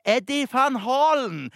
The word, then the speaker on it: Eddie van Halen: Det var han Eddie van Halen. Ja, ikke helt 100 riktig. Jeg tror faktisk at det uttales Eddie van Halen.